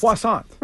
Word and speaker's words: croissant: Croissant. – Right. – Excuse me, miss, may I have a croissant? – Let me get a croissant.